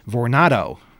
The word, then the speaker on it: Vornado: We also got a few emails about the hotel situation, including a clarification about the company that owns Hotel Pennsylvania, Vornado, spelled like tornado except with a V.